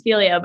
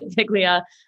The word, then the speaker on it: Figlia: So it's actually pronounced Philia, but you shouldn't feel bad because most everybody calls it Philia and also thinks that there's figs in it.